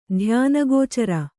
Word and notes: ಧ್ಯಾನಗೋಚರ: dhyāna gōcara

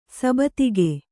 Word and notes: ಸಬತಿಗೆ: sabatige

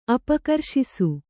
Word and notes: ಅಪಕರ್ಷಿಸು: apakarṣisu; kannada

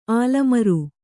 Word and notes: ಆಲಮರು: ālamaru

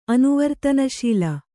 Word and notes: ಅನುವರ್ತನಶೀಲ: anuvartanaśila

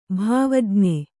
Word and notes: ಭಾವಜ್ಞ: bhāvajña

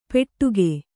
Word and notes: ಪೆಟ್ಟುಗೆ: Kannada

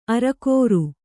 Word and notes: ಅರಕೋರು: arakōru; Kannada

